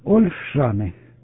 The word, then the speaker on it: Ольшаны: А ну поправь меня, это Ольшаны, я правильно назвал или нет, прием? – Да-да. Все правильно. Ольшаны, это Харьковская область. Все правильно принял, прием.